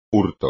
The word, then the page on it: hurto: espagnol